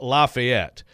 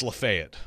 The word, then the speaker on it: Lafayette: Okay, so here's a town that in Indiana is pronounced, so people know, is pronounced Lafayette. So here I will say it's Lafayette. – Correct.